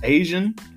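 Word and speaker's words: Asiahn: I think her name is Asian. It's spelled like Asia with H-N on the end of it. So that's how I'm saying it.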